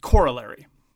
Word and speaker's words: corollary: And Americans pronounce it corollary. And that's the American pronunciation. That's the emphasis on the first syllable as opposed to the emphasis of the second syllable, which is the more traditional British pronunciation.